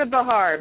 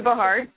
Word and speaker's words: Bahar: It's Lisa Bahar, but don't worry. People butcher it all the time. I just go with it, but it's Lisa Bahar.